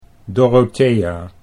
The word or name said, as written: Dorothea